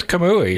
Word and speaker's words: Kamui: Is it Kamui? – It is Kamui.